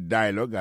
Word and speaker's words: dialogue: Comme n'a pas mis accent, sous bel accent en anglais, sous belle prononciation en anglais, le mot dialogue a gagné deux syllabes là-dedans. Daily dialogue, alors, il pesait plus sous premier syllabe là.